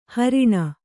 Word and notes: ಹಾರಣಿ: hāraṇi

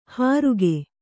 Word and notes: ಹರುಗೆ: haruge

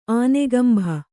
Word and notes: ಆನೆಗಂಭ: ānegambha

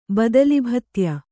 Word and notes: ಬದಲಿಭತ್ಯ: Kannada

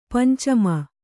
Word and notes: ಪಂಚಮಿ: pancami

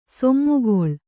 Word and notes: ಸೀಮೆಗಳೆ: sīmegaḷe

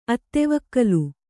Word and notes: ಅತ್ತೆವೊಕ್ಕಲು: attevokkalu; Kannada

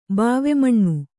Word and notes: ಬಾವೆಮಣ್ಣು: Kannada

